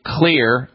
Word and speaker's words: Xlear: I would be using Clear, X-L-E-A-R, that's how you spell it, Clear, X-L-E-A-R, their rescue nasal spray.